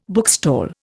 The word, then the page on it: bookstall: Indian